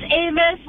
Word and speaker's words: Avis: It's Avis, like the rental car.